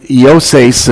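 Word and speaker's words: Yoses (Ἰωσῆς): The Bible tells us in Acts chapter 4, Yoses is really how you would pronounce his name in the time and out of the Greek.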